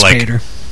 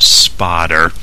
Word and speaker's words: Spader: Actually, it's pronounced Spotter. That was a conversation I overheard when I worked at Intel. It's like listening to the woman in the cubicle across from mine explaining to one of her friends how she found out that James Spader's name is actually pronounced Spotter, which is complete bullshit.